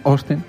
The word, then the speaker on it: Austin: Austin. – Con O, ¿no? La A, y eso. – Bueno, la A no la dicen. Me fijé, Austin, y no lo decían. Decían Austin.